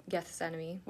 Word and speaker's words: Gethsemane: And think about how Jesus, okay, so right before every big chapter in the Bible, you can look at the Garden of Gethsemane. Did I say that right? – Gethsemane.